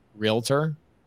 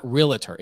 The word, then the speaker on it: realtor: I've been teasing people about the way that people pronounce realtor, that everybody says it realtor. It's like, it's not realtor, it's realtor.